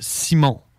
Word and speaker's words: Simon: He was drunk as fuck, and he was like, "Hey, because the name Simon in French is pronounced Simon."